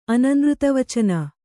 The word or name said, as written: ಅನನೃತವಚನ